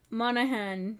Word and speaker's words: Monaghan: Mon- Monahan, did I say it right? – Very good. well done. Very well done.